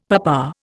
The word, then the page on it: papa: Indian